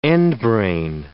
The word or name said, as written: endbrain